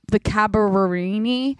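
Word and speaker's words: Carabinieri: I've come to find out that there are two different police branches in all of Italy, but the Cabarini or whatever. – No, that's perfectly pronounced.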